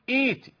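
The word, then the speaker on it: ائتِ: عندما نقرأ في الوصل نحذفها، وعندما نبدأ بالهمزة ننطق صوتها فنقول إيتي، إيتي بقرآن، إيتوني بكتاب. اتفقنا لا تقول إئ ولا أ ولا أ، اتفقنا على هذا.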